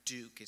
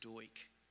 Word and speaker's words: Duch: But his nickname was Comrade, and most people say Comrade Duke. It's spelled D-U-C-H, but it's actually pronounced Doik, like D-O-I-K.